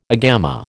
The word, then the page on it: agama: American English